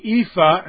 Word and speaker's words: EAFE: The World Index means everybody, and the EAFE, and that's how it's pronounced, I didn't make it up, E-A-F-E, was everybody except the United States.